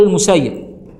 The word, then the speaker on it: المسيب: فهنا المسيب مكتوبة بالفتح، يبقى نقول المسيب. ها ليه؟ عشان الصدق في الرواية بالفتح أولى من أننا الدقة في النقل.